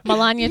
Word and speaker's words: Melania: Melania Trump.